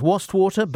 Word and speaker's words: Wastwater: Wastewater. Wastewater. – I think it is pronounced Wastwater, but yes, that is the deepest lake in England.